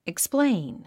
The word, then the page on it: explain: ikspléin; イクスプレイン